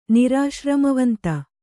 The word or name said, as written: ನಿರಾಶ್ರಮವಂತ